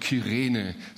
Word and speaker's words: Kyrene: Das ist das, was wir zunächst mal von Simon von Kyrene, so wird das ausgesprochen, dieses Z, was wir über ihn erfahren.